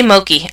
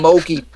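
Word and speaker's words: Mokey: Now we're going to have a big Facebook war with all the Moki emailing me. – She's just... – Thank you. – Her name rhymes with Mopey.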